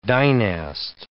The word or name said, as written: dynast